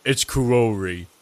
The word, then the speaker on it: Karori: Carrie. – It's Karori. – I'm never going to be able to say that correctly every single time. – I know you can do it. Come on, say it with me. It's Koo. – Koo. A. – No, O.